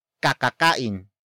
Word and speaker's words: kakakain: In this context, sa is a causative marker that introduces the cause of the stomach ache, and that is kakakain. Kakakain. Kakakain. Kakakain is spelled as K-A-K-A-K-A-I-N.